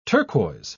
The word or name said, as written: turquoise